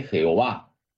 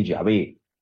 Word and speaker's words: Yahvé: No se dice Jehová, se dice Yahvé. Las cuatro letras sagradas, Y, H, W, H se pronuncia Yahvé,